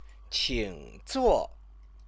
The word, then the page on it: qingzuo: Chinese